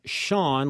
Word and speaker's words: Chaon: By the way, if you're looking for his work, his name is pronounced Shaun, like the man's name, but it's spelled C-H-A-O-N, Dan Shaun.